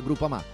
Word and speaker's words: Groupama: Ah no, si pronuncia Groupama.